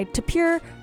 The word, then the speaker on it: Tapir: What we just heard was Mountain Song, which is the closing track off of by Tapir... Tapir. I recently learned it's pronounced tapir, like the animal.